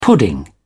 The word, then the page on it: pudding: British English